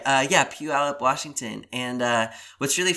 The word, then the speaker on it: Puyallup: If you look at how it's spelled, Puyallup it's kind of like Cuyahoga in Cleveland. Oh, it's oh, yeah. it's spelled P U Y A L L U P.